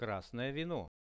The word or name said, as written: krasnoe vino